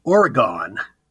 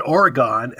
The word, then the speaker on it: Oregon: Well, you're from California, so you would pronounce it correctly. But here on the East Coast and in the Central States, we all pronounce Oregon, Oregon. And that drives Oregonians crazy.